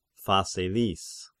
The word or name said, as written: Phaselis